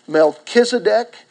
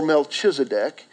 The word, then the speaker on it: Melchizedek: It can either be pronounced Melchizedek or Melchizedek.